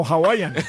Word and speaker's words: Hawaiian: Hawaiian Pizza Day. – No, Hawaiian. Al principio lo dijo bien.